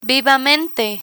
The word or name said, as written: vivamente